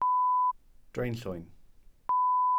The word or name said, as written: draenllwyn